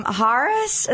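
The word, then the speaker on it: Harrah's: We stayed at Harris.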